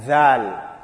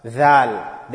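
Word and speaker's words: dhal (ذال): Dhal. The one which is after dal. So the easy way to know the pronunciation of this letter, dhal, is you must make sure the tongue comes out.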